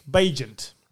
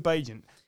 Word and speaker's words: Bagent: but Tyson Bagnett? You tell me, because I've heard it pronounced in several different ways, and I feel like I'm getting it wrong. – Bagent. – Right. Tyson Bagent.